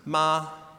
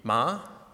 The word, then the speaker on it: ma: The first tone is high and flat, pronounced ma. The second tone is a rising tone, which is similar to a questioning tone in English. We use it over the course of a sentence, but pronounced here would be ma. The third tone is a falling rising tone, pronounced ma. And the fourth tone is a falling tone, a sharp falling tone, pronounced ma. There's also a neutral tone that you can see there, which is just pronounced ma.